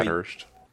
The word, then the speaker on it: Bathurst: Yeah, Bathurst.